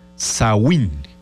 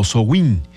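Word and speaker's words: Samhain: Los celtas tenían una celebración que se llamaba Sawin. Es un vocablo celta, ¿eh? O sea, eso quiere... así es como se pronuncia, Sawin o Sowin.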